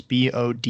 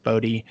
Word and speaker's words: Bode: If you aren't familiar with her work, the brand is Bode. It's pronounced Bode.